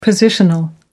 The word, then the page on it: positional: American English